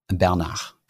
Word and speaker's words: Bernard: Der Bernard Arnault, genau den. Bernard. Ja, Bernach, genau.